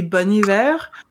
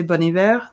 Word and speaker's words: Bon Iver: There would be an H before the Iver, because the H is not pronounced in French. So they left it off. I don't know if it's a nod just to French pronunciation or if they just liked it better. But so it's one of those things that I never know how to pronounce, because if you say Bon Iver, then you sound like a pretentious jackass. But technically it's not Bone. Although then in American, maybe it is Bone.